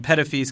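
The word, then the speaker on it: Petitfils: And Pettifees, because I know you're wondering, is spelled P-E-T-I-T, like petite, F-I-L-S.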